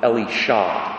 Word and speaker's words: Elisha: In Hebrew, you say Eliyah and Elisha, with the emphasis on the last syllable. It's the only way they sound different, but they're two different people, I assure you.